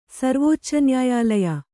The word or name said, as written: ಸರ್ವೋಚ್ಚನ್ಯಾಯಾಲಯ